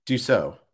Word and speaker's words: Dussault: it online so we did it online and we got the draw and phil dussault is it how do we say it dussault dussault dussault okay so like french i took french so phil dussault was in our draw and Tyler Young was in our draw.